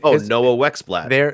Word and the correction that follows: Noah Wexelblatt: Their name. – Noah Wexelblatt.